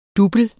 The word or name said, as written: double